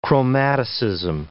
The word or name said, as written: chromaticism